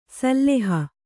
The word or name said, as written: ಸಿಲ್ಹ